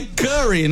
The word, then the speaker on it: Curry: Eu entendi Curi. – É Curry, né? – Eu entendi outra coisa.